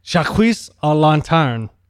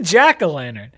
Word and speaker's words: jack-o'-lantern: Jacques a lantern. So it is. – it's the jack o' lantern.